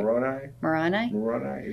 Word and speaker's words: Moroni: We had missionaries tell us from their Bible that Moroni, did I say that right? – Moroni, yep.